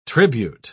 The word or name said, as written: tribute